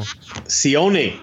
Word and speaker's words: Sione: Plus, we've got the bad guys, which is the 1-2-3 Kid, British Bulldog, Fatu, Razor Ramon, and Sion. I pronounced that all wrong. – Sione.